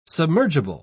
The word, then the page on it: submergible: British English